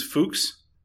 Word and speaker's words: Fuchs: I think his name is Fuchs. – Fuchs. – Even though it's spelled, as you can see up there, Fux. Just Fuchs.